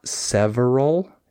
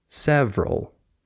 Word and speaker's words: several: Like it's not several, it's several. You just skip the syllable.